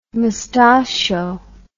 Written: moustachio

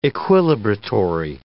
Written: equilibratory

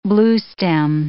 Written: bluestem

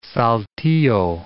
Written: Saltillo

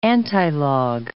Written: antilog

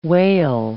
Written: wail

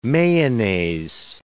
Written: mayonnaise

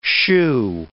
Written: shoo

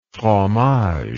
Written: fromage

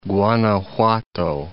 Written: Guanajuato

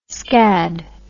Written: skad

